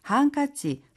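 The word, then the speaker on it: 한카치 (ハンカチ): 한카치, 손수건인데요. 발음 팁 있을까요? – 하이, 한카치는 손수건을 뜻하는 영어 행커치프의 일본식 발음인 것도 알고 계시면 좋겠습니다.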